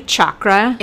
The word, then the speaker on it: chakra: And is it actually chakra? – It's chakra. – Chakra. – Yeah. – Okay. – The H is silent.